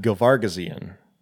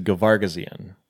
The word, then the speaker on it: Gervagizian: One is from Jill Gervagizian, I think is how it's pronounced. – I did like one of the like, how do you pronounce this name things, and they said Gervagizian, because there is that other G in there. There's a second G.